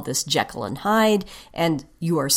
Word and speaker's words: Jekyll: Anyway, that's why I'm saying Jekyll.